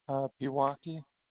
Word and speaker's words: Pewaukee: How do you say that city? – Pewaukee. – Pewaukee. Okay. I don't know. Wasn't sure. – It's Pewaukee.